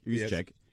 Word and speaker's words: Juszczyk: Juszczyk. Is that how you say it? – I think pretty close. Wow. Pretty I mean, I'm not even 100% sure, but I think it's Kyle Juszczyk. Juszczyk. Yes. Juszczyk. There you go.